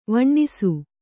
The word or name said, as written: ವಣ್ಣಿಸು